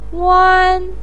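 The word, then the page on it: wan: tone 1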